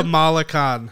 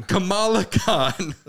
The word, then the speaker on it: Kamala Khan: You just said it wrong. – Oh no, Kamala. Kamala. – Go back. What is it? What is it again? – Kamala Khan. – Kamala Khan.